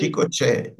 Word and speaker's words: Chico Che: The next song is, I can't say it right, Chico Che. Did I? – Chico Che, yes.